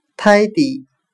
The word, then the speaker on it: tidy: 单词 tidy，t i d y，整齐的，整洁的。我们就可以通过它的发音联想到汉语的泰迪，再由泰迪联想到泰迪熊。